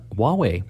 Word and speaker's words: Huawei: We will also be talking about a brand new phone from a company called Huawei. – Who? – Huawei.